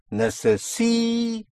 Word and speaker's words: necesito: So again here, on the penultimate syllable, necesito. Yes.